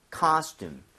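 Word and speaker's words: costume: 그래서 코스튬보다는 이런 느낌이에요. 카스튬. 카스튬.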